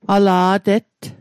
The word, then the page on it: ala' tett: Mi'gmaq